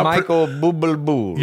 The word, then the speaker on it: Bublé: Michael Bublé. – You're not pronouncing the accent at the end.